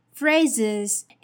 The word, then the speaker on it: phrases: Phrases. Its original vocabulary is phrase, phrase, P H R A S E, but notice that the S E here is pronounced as Z, Z instead of S, S.